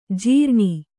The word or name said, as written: ಝರಣಿ